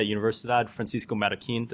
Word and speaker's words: Universidad Francisco Marroquín: Today, I have the great pleasure of catching up with Carroll Ríos, who's a professor and trustee at Universidad Francisco Marroquín.